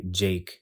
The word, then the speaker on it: JVKE: It's called Golden Hour by Jake. At least I think that's how you pronounce it. It's like J-V-K-E.